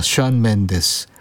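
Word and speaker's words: Shawn Mendes: 이어서 캐나다의 싱어송라이터, 스펠링이 약간 다른데 발음은 션입니다. 션 맨데스.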